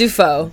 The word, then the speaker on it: Dufault: It's Dufault. – Dufault. Yeah. Dufault. That was close, you know. – It's like Willem Dafoe.